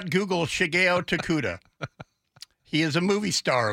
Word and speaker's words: Shigeo Tokuda: S-H-I-G-E-O, first name, last name Tokuda, Tango, Oscar, Kilo, Uniform, Delta, Alpha, Tokuda, just like it sounds.